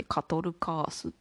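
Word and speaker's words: 4/4カトルカース: 4 分の 4 と書いてカトルカースって読む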